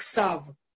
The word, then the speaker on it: тав: Букву сав произносят только в некоторых ашкенадских общинах. Э, вот, э, и э, мы тоже знаем, что она читается как сав. Ее читают в основном только в молитвах, в обычной речи. Эта буква произносится только как тав.